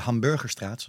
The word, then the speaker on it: Hamburgerstraat: Ja, maar de Hamburgerstraat, zoals ze die in Utrecht noemen, de klemtoon op de bu. – Oh. – Ja, ik vraag me niet waarom. De Hamburgerstraat, dat is dan een hele oude straat.